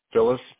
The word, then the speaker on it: Phyllis: Phyllis, P-H-Y-L-L-I-S. – Phyllis. – E. Gartery, D-A-U. G-H-E-R-T-Y.